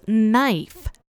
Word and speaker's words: knife: Finally, a yellow-haired girl in the class yelled out, "It's knife! The K is silent!"